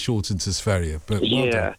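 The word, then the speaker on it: Sverige: No, Sverre is the right, correct pronunciation.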